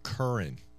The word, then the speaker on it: Curran: Rex, it's Joseph Curran. That's the correct pronunciation, the Attorney General.